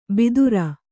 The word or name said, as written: ಭಿದುರ